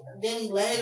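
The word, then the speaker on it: DaniLeigh: I oh, like, baby, I read the whole story because I used to, I really liked Danny Lay, Danny Lay or Lee, I'm gonna say Lay, Vinny Lay, that's how she pronounces it.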